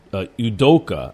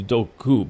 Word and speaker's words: Udoka: Udoka. I thought it was Udoku.